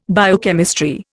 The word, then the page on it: biochemistry: Indian